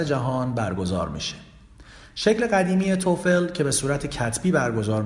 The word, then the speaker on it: TOEFL: بعد از معرفی IELTS این بار میخوام در مورد آزمون TOEFL باهاتون صحبت کنم. لغت TOEFL یا با تلفظ درست TOEFL مخفف عبارت Test of English as a Foreign Language هست.